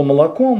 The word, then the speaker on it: молоко: Значит, слово «молоко» мы будем произносить так. «Молоко» – это единственно правильное и общепринятое произношение этого слова.